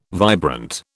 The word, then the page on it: vibrant: British English